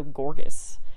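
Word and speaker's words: Gorges: And they said in this YouTube video that how you pronounce it is Sir Ferdinando Gorgas, which is literally the last way I would have thought to pronounce that.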